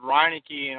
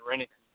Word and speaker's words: Reineke: But I just know that we usually pronounce it Reineke, and I don't know, way back then we started pronouncing it Reineke.